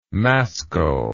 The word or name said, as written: mascle